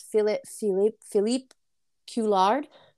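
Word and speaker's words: Philippe Couillard: Philippe Coulard. Sorry if I butchered that. – No, you did actually a really good job.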